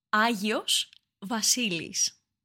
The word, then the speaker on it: Agios Vasilis: Agios Vasilis. – Agios Vasilis.